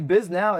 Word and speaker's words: Bisnow: The guy, actually, Biz Now is someone's last name, Mark Biz Now. But it's pronounced Biznow. – Blowing my mind. – Biznow, it's spelled the exact same way, B-I-S-N-O-W.